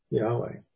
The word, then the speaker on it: Yahweh: So Jehovah is a German pronunciation of Yahweh. So that's where it came from. The J in what we transliterate is, or translate as a J in Hebrew is a Y sound.